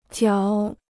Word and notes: 嚼: jiáo

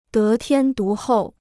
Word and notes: 得天独厚: dé tiān dú hòu

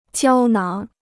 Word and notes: 胶囊: jiāo náng

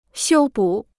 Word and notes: 修补: xiū bǔ

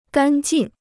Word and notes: 干劲: gàn jìn

